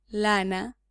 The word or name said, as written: lana